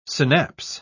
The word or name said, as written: synapse